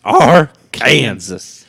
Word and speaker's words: Arkansas: It's pronounced Arkansas.